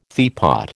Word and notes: teapot: American English

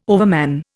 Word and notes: overman: Indian

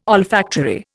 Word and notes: olfactory: Indian

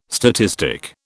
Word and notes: statistic: British English

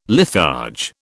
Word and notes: litharge: British English

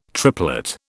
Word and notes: triplet: British English

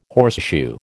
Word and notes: horseshoe: American English

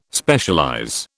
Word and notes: Specialize: British English

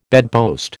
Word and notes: bedpost: American English

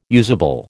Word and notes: usable: American English

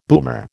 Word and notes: boomer: American English

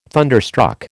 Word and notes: thunderstruck: American English

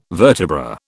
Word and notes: vertebra: British English

